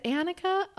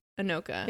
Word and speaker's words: Anoka: Like, is it Annika? Oh, yeah. – Shit. – Anoka. – No, it's Anoka.